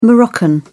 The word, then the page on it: moroccan: British English